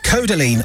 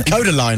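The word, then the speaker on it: Kodaline: Now, some of the bands that are going to be playing there are Codaline. – Codaline, I would imagine.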